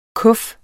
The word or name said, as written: kuf